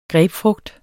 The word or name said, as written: grapefrugt